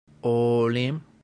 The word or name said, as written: olim